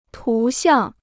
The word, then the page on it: 图像: tú xiàng